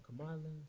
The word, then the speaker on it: Kamala: Kamala. – Kamala. – Kamala. Yeah, I don't know about that one, man. She know. got How roll, you feel about her? Kamala. Roll off your tongue. Kamala.